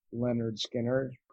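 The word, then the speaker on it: Lynyrd Skynyrd: Like, you know, the one that's like pronounced Leonard Skinner.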